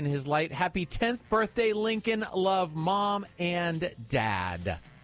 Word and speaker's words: Lincoln: Well, it says Lincoln like the president.